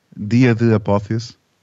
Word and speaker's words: Dia de Apotheus: How do you say it in Portuguese? Dia de Apotheus. – That was pretty close. Dia de Apotheus.